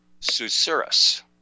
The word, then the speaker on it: susurrus: But the new word that I learned is pronounced susurrus. Now, this is a strange word. It's spelled S-U-S-U-R-R-U-S.